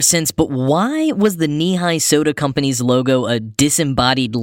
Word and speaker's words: Nehi: Well, being spelled in the non-obvious way, Nehi, N-E-H-I, the company, had to do a little subliminal messaging and brand awareness to instruct people on the proper Nehi pronunciation, thus featuring a logo of a leg ending at knee height.